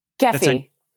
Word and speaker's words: Kefi: Kefi. Say it again. Kefi Collective. – All right. – Kefi.